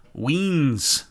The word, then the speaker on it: Wiens: Starting with a Twitter post from Kyle Weens, I believe is how you pronounce it. Weens, W-I-E-N-S.